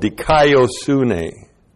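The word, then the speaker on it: dikaiosune (δικαιοσύνη): It's called dikaiosune. D-I-K-A-I-O-S-U-N, long E, dikaiosune.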